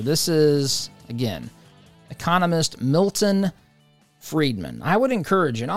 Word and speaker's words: Friedman: His last name is spelled Friedman. It kind of looks like fried man, but it's Milton Friedman.